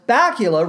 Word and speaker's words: Bakula: Bakula rhymes with Dracula.